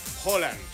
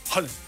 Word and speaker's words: Haaland: Bueno, Alfredo González, comentarista de Fórmula Deportes, ¿cómo se pronuncia? – Holland. – Holland. – Holland. – Es con O. – Y es rápido, es Erling Haaland. – Haaland. – Así es su nombre. – Pero es con doble O. – No, es con A, con doble A.